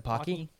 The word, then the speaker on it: Pocky: The Pocky.